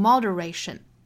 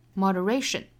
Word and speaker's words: moderation: 像 moderation， moderation 是四个音节，如果大家今天没有听早读的话，乍一看这个词可能生词啊，不会读，可能会读成 moderation。但是如果我们查字典确定之后呢，知道了重读应该是在第三个音节上面的 moderation。